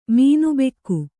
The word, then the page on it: ಮೀನುಬೆಕ್ಕು: mīnu bekku